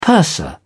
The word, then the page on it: purser: British English